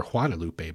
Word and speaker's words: Guadalupe: But what I'm here to tell you is you reach a point where you reach what is known as the Guadalupe Box, or Guadalupe Box, which would be the more proper pronunciation.